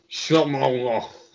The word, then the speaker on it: Chamonix: It's pronounced Chamonix.